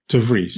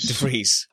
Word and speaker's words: De Vries: De Vries. – De Vries.